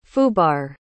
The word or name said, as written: foobar